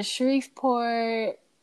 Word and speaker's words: Shreveport: I know Shreveport. Is it? That's how you say it? Shreveport? – Shreveport.